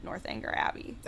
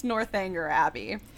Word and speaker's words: Northanger Abbey: she messaged us and she said Northanger Abbey she pronounces it, and I trust her, so I think it's Northanger Abbey.